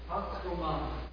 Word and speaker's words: Pax Romana: During this time, there was, I might be pronouncing this wrong, but bear with me. It's called Pax Roma. It means Roman peace. Is that how you say it? Pax Roma? Pax Roma, okay. I was looking to my scholars. Romona? – Pax Romana.